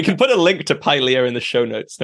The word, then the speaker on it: Pylea: And you've mentioned, how do I say it? Pylea? Is that how you said it? Or Pylea. – Pylea. – It's named after a plant.